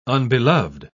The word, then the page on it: unbeloved: British English